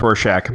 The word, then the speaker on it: Rorschach: Uh, no, it's Rorschach. Yeah. But like it's Horshack um, from uh, Welcome Back, Cotter.